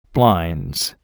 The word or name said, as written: blinds